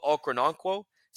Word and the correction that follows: Okoronkwo: Is that how you it's, say it? Obo, yeah, Obo Okoronkwo. – Okoronkwo.